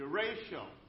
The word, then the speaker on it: derecho: Sometimes, as last summer, parts of Iowa endured derecho or derecho? How do you pronounce it? Derecho.